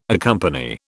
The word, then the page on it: accompany: British English